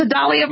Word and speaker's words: Sedalia: Yes, yeah, Sedalia rhymes with Dr. Dahlia.